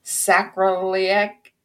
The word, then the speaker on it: sacroiliac: Has over 20 years of experience working in the physical therapy world, specializes in complex spine and sacroiliac.